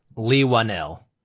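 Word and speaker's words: Leigh Whannell: Lee Whannell.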